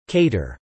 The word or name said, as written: cater